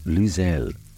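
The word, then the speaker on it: l'oseille: Sorrel in French is pronounced luzel and spelled L apostrophe O-S-E-I-L-L-E.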